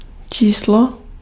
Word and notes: number: American English; /ˈnʌm.bɚ/

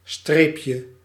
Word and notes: dash: /dæʃ/

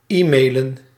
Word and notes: e-mail: /ˈiːmeɪl/

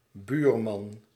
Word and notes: buurman: /by:ɾ.mɑn/

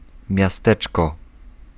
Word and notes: ville: [yn vil]; /vil/

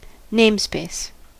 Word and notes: namespace: /neɪmspeɪs/

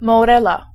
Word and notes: albicocca: IPA: /al.biˈkok.ka/

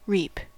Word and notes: косить: /kɐˈsʲitʲ/

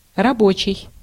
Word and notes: travailleur: [tʁa.va.jœʁ]; France

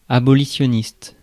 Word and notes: abolitionniste: France; /a.bɔ.li.sjɔ.nist/

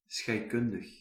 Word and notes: chemical: /kɛ.mɪ.kəl/

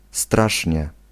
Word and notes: extremely: [ɪks.ˈtriːm.li]

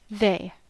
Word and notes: hän: [hæn]

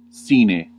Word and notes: sans: France

